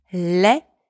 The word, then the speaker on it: lait: The first one, spelled L-A-I-D, means ugly. The second one, spelled L-A-I-T, means milk. In both of these words, the letter L is pronounced L. The letters A and I together are pronounced E, and the final consonants, D and T, are both silent.